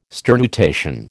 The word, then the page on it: sternutation: American English